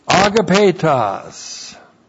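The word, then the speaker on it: agapetos (ἀγαπητός): Agapetos. Looks like this in the Greek. That's A-G-A-P, long E-T-O-S, agapetos.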